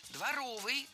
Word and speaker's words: дворовый: Дворовых соревнований, дворовые – это неверно. Правильный вариант – дворовый, дворовые соревнования. Прилагательное, образованное от существительного «двор» – дворовый, с ударением на втором слоге и окончанием «ый». Других вариантов словари не фиксируют, так что дворовые соревнования.